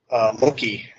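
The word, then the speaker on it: moncaí: For instance, Git handles remote repositories, and basically you would just add what I'm building with SignSignal, which is actually called Monkey, if you're wondering how to pronounce the name of it. That's pretty much the pronunciation. It's Irish for monkey, the way I spell it. So how is it spelled? – M-O-N-C-A, and then it's got a hyphenated I.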